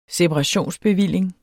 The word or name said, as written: separationsbevilling